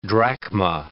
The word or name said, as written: drachma